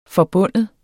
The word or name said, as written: forbinde